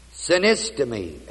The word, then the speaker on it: synistemi: Commendeth is the Greek word synistemi, S-U-N-I-S-T-E, long E, M-I, meaning give proof. But God commendeth, gave proof of His own love towards His ace, E-I-S, us, because in that, because while we were yet ones who missed the mark, sinners, Christ died for what?